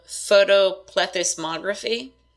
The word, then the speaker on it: photoplethysmography: So the photoplethysmography, which I said I wasn't going to say it again.